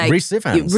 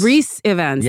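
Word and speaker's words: Rhys Ifans: Oh, Rhys Ivans. – Rhys Ivans, yeah.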